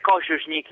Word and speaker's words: Kosunic: E Kosunic, si pronuncia così il cognome intanto? – Dovrebbe essere Kosunic in realtà, perché in ceco l'accento va sulla prima sillaba della parola normalmente.